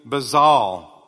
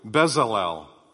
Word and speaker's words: Bezalel: And then, of course, in Exodus 31, verses 2 through 4, God chose a man named Bezalel, or I got to pronounce this properly, Bezalel, to serve as the general contractor.